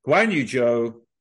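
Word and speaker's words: Guanyu Zhou: Guan Yu Zhou. That's right. This year we pronounced his name correctly.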